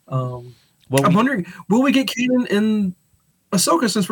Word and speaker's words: Kanan: It's Kanan, right? – Yeah.